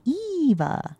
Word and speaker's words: Eva: But people go Ava thinking they're saying my name in Spanish, and they're not. It's because it's Eva or Eva, but it's never Ava. – It's never Ava. – It's never with an A. So I just go, no, it's with an E. Eva.